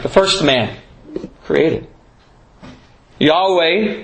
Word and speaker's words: Yahweh: But in Hebrew, if we were to pronounce it, it's Yahweh.